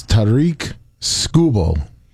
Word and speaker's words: Tarik Skubal: How to say his name is, it's Tarik Skubal.